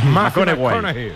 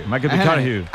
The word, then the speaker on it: McConaughey: Hay que ir corrigiendo la pronunciación. – McConaughey. – McConaughey.